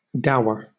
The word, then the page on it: dour: British English